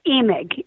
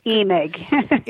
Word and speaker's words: Emig: Emig, with a long E, Emig.